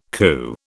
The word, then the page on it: coo: British English